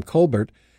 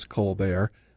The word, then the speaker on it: Colbert: According to reliable sources, most of the family pronounces the name Colbert. It's actually Irish, not French. Late night host Stephen, the candidate's brother, uses Colbert, but the rest of his family do not.